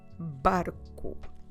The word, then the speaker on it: barco: B A R C O barco This one has an R, the R, but it's only one, it's a single R, so we don't require all that vibration. It's just a R, a bit like the English R, just slightly different in pronunciation terms. So we go u barco, bar. The, temp, the tip of your tongue will hit just before your teeth, just once, just once. To bar, bar, barco and the plural, quite easy this one.